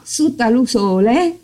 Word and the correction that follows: Sutta l'usule: giusto? – Sì, Sutta l'usule. – Sutta l'usule, più stretto alla U, ho capito.